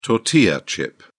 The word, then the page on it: tortilla chip: British English